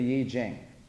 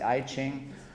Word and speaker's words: Yi Jing: How many are familiar with the Yi Jing? It's called the Book of Changes. Sometimes it's pronounced the I Ching.